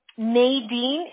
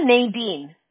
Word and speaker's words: Nadine: Nadine is my first name. Medina is my last name. – Nadine? – Uh-huh, Nadine. Like Nadine in Spanish. There's not a way to pronounce it in Spanish.